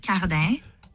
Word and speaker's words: Cardin: My name is Charlotte Cardin. – Cardin? Wieso denn plötzlich Cardin? Wir dachten Cardin, also das wird geschrieben C-A-R-D-I-N.